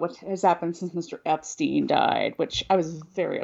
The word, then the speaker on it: Epstein: Even though we clearly have declared, have evidence, go back and listen to our episodes where we clearly state why Brian's last name is pronounced Epstein.